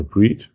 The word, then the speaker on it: Breed: Und die Internetseite heißt Mangalitza Bread. Oder Breed, wie spricht man es aus? Ist es Holländisch, ist es Englisch? – Breed, okay. Brüten, ja. Ja, Mangalitza Breed.